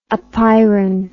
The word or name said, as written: apeiron